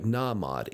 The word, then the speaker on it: Nahmod: It's spelled like Nahmod, N-A-H-M-O-D, but it's Nay. – Correct.